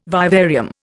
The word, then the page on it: vivarium: Indian